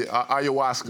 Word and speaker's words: ayahuasca: Why did you feel as if you need to, I don't even know how to say it, ayahuasca? – Yeah, ayahuasca.